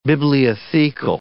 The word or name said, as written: bibliothecal